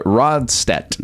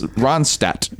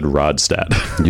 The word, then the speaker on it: Ronstadt: It's Ronstadt. – You said Rodstadt.